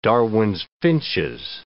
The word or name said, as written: Darwin's finches